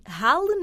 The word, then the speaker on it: Halle: Hoje apanhamos na rede a Luísa Laranjo em Halle, na Bélgica. Não sei se estou a pronunciar bem, onde vive Sim. desde 2010. Luísa, seja muito bem-vinda. – Obrigada, e a pronúncia está perfeita.